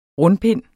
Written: rundpind

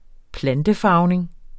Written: plantefarvning

